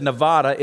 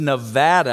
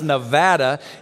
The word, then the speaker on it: Nevada: And Noah, you said Nevada. It's Nevada. I got to help you, my brother. It's Nevada. You know immediately if you're from Las Vegas or not by how you pronounce the word Nevada. It is Nevada.